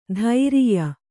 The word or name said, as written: ಧೈರಿಯ